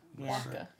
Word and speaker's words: latke: No, it's just it's a potato latke. It's a... – Huh. – Latke. – I say it right? – Latke. – Latke, yeah.